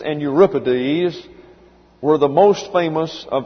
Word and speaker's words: Euripides: A-E-S-C-H. I left the C out. A-E-S-C-H-Y-L-U-S. That's Aeschylus. And then Sophocles. S-O-P-H-O-C-L-E-S. And then Euripides. E-U-R-I-P-I-D-E-S.